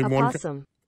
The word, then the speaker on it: opossum: There's only opossum. – One. I'm sorry? – Opossum. – Oh, I hear an O there. – You are correct.